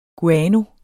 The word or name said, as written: guano